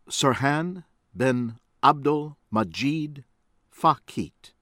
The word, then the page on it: Serhane Ben Abdelmajid Fakhet: American English